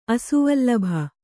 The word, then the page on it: ಅಸುವಲ್ಲಭೆ: asuvallabhe